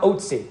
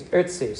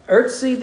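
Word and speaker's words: Ötzi: It's not Otzi. I think it's Ertzi, something like that.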